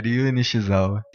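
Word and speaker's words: Ryue Nishizawa: É Ryu Nishizawa. – Ryu? Ryu é tipo o Ryu do Street Fighter.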